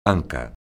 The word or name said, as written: anca